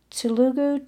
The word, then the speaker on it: Telugu: Telugu, T-E-L-U-G-U.